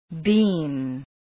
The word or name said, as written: bean